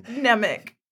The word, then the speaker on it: Nemec: We've got Vel, the leader, Cinta, the hot one, Skeen, the sketchy one, Vemmick, the soft boy hot one. – Nemec. It's Nemec.